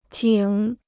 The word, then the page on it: 井: jǐng